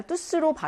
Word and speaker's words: tous: 뚜스 모두라고 하는 것의 대명사일 때는 뚜가 아니라 뚜스로 발음이 난다라고 했어요.